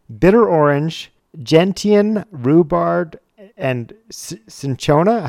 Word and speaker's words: cinchona: Maybe this is something different, but it's spelled C-I-N-C-H-O-N-A. That was different than the caquina, right? – Yes, I believe so. – Okay. So not caquina, but cinchona.